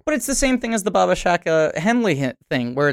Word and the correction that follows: Shabaka Henley: Shabaka Henley.